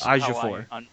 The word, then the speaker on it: Ejiofor: Chiwetel Ejiofor. – I would tell Ejiofor is Ejiofor. – I'm led to believe that's how it's pronounced.